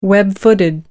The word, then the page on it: web-footed: American English